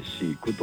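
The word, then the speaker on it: 四十九: はい、今回ですね、シーク、漢数字でも 4 と 10 と 9、 49 と書いてシークという映画を主演させていただきました浅野寛介です。お願いします。 – タイトルが漢字で 4、 10、 9 と書いて、読み方がシークと読むんですね。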